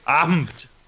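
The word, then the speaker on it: APIM: It is Daryl Miller, who is now a developer with Microsoft on the Azure API Management Team, which he tells me is pronounced AMPT.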